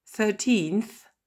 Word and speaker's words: thirteenth: Je lis colonne par colonne et je continue avec fourth, fifth, sixth, seventh, eighth, ninth, deuxième colonne, eleventh, twelfth, thirteenth, fourteenth, fifteenth, sixteenth, seventeenth, eighteenth, nineteenth. On retrouve l'accent tonique en deuxième syllabe.